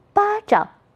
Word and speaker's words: 巴掌: 再读读词鼓掌、巴掌。在这里掌读成轻声，巴掌、掌心。